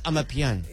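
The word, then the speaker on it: Amapiano: So it's I'm a piano. – Yeah. Yeah. I'm a piano. – Silent O. Got it.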